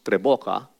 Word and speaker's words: Tres Bocas: The spelling is actually T-R-E-S-B-O-C-A-S. Tres bocas. Pronounced treboca, which means three voices in perfect harmony singing together.